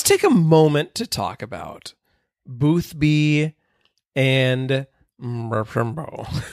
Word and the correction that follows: Marcus Archambault: Marcus Archambault. Thank you, Marcus Archambault, whose name I will never be able to pronounce in my, in my lifetime.